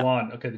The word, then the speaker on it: Guan: Guan. Okay, G, it's a hard G.